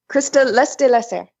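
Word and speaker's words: Christa Lesté-Lasserre: Krista Lester.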